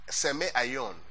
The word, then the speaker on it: semeion: Now, signs is from the Greek word Simeoni or Simeoion. Let me spell it for you, please. S-E-M-E, not S-I, S-E-M-E, Semeion, I-O-N. Simeon. Okay, that's what she said. Then the Hebrew word is oath, O-T-H.